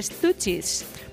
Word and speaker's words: Stooges: Antes pronunciamos mal el nombre del grupo este. Escrito D-S-T-O-O-G-E-S. Y esto se pronuncia de Stooges.